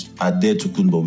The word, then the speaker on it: Adetokunbo: So it's pronounced Adetokunbo in the Greek way. Yeah. But the Nigerian way, the way, you know, I knew my name growing up was Adetokunbo with D and with a B.